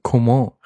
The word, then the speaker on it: Coman: Und endlich wird Kingsley Coman beziehungsweise Coman richtig ausgesprochen.